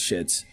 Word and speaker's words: Schitt's Creek: I said Schlitz, but I think it's Schitt's. – So, it's weird how it's yeah. how it's pronounced. Yeah, they make it sound like it's a curse word.